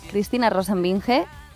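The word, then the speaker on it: Cristina Rosenvinge: Los artistas que os doy son Joaquín Sabina, Cristina Rosenbinge, ¿se dice así? – Sí.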